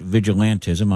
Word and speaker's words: vigilantism: By the way, our producer looked it up, and it is, in fact, vigilantism. I've been mispronouncing it as vigilanteism. That is not correct.